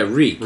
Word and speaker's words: Reek: Yeah, Reek. – Reek rhymes with meek.